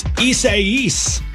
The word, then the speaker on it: Isaias: I-S-A-I-A-I-S. – Isosceles. I don't know. I got nothing. – It's Isaias.